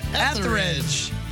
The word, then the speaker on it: Etheridge: Melissa 8th Ridge. – Oh, okay. Is that close enough? – Yeah, that's close enough for me. – Melissa Etheridge.